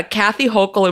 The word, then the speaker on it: Kathy Hochul: I got a Kathy Hochul impression in the hopper. Is that how you say her name? – I think so. I think you've really nailed it.